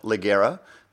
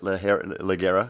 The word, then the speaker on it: Ligera: It may be spelt Ligera, but don't pronounce it Ligera because you will get in trouble. Well, it is Ligera.